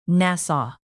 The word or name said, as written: nassau